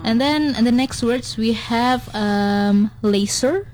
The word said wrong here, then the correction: leisure: Oh, no. – Laser. – Leisure. – Yeah. Okay, so the right word to pronounce leisure, mm-hmm.